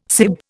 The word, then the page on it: sib: Indian